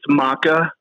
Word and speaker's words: Mauka: Yeah, you are the CEO at, am I saying this right, Mauka? – Close. It's Maka, MAUKA, logistics. It's Hawaiian.